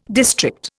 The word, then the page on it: district: Indian